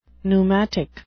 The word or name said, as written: pneumatic